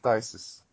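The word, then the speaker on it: Phthisis: Ptisis. Remember that? Or Ptisis. – Yeah. I think it's Ptisis. It's just Tisis. I think that's the pronunciation.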